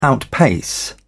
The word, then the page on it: outpace: British English